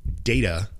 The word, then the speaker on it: data: D-A-T-A, porque es D-A-T-A, data, the data, the data, en un acento británico. Pero yo lo digo data porque nosotros somos perezosos en inglés americano y muchas veces pronunciamos la T como una D.